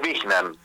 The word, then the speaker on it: Wijnant: No está muy bien, pero ok, sí, es Wiñant.